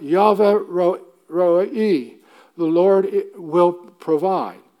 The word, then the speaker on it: Yahweh: And all of these, and there are 40, 50, 60 of them in the Bible, all these are telling you what Yahweh, Jehovah, better pronounced Yahweh, does for you and for me, his salvation.